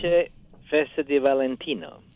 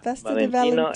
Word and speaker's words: Felice Festa di Valentino: Felice Festa di Valentino. – Ah. Festa di Valentino.